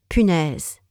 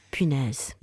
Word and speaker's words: punaise: Yeah, how do you say it in French? Punaise? – Punaise. Punaise.